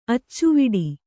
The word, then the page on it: ಅಚ್ಚವಡಿ: accavaḍi